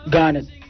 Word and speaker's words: Garnet: Garnet D.